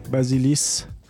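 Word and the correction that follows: Vassilis: Vasilis, Vasizeros.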